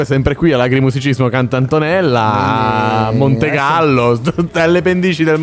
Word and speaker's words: CantAntonella: Un ringraziamento a Max Luciani, a tutto lo staff che collabora, a tutti gli artisti, all'Agrimusicismo CantAntonella, l'ho detto giusto.